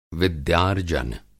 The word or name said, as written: विद्यार्जन